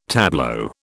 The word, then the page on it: tableau: British English